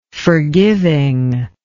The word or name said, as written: forgiving